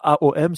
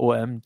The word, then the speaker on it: Om: Et ça se prononce soit AOM, soit OM, tu peux le dire comme tu veux.